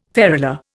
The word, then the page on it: ferula: Indian